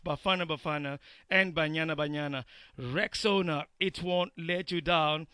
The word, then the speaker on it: Rexona: But guys, this is Rexona, like Rex owner. It's Rexona. This new name, guys, comes with a fresh new look and advanced body heat technology.